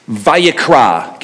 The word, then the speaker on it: Vayikra: And in English, you would pronounce it something like this. Vayikra. Give me a Vayikra.